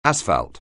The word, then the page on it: asphalt: anglais